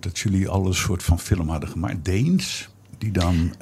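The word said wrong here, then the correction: Daens: Daans heet hij. Maar ik snap dat, want het is AE, dus dan weet je nooit zo goed hoe je het moet uitspreken.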